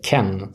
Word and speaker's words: Kean: Och sen på topp startar antingen Mandzukic eller Moise Kean, som jag har förstått att det ska uttalas i Italien i varje fall.